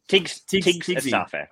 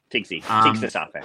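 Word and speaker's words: Tigst Assefa: I don't know how to say her first name. – Tigs Asafa. Tigs Asafa, yeah.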